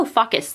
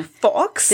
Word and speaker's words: Fawkes: Which when I was reading Harry Potter and obviously like I'm not as huge of a super fan as Megan, but when I was reading it, I was like, oh, Faucus the Phoenix. Faucus the Phoenix. And then it wasn't until, I guess, the movie where they say his name. – Fox? – They're like, oh, Fox. And I was like, oh, Fox. I get it.